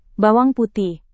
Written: bawang putih

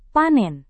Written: panen

